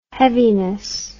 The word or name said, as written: heaviness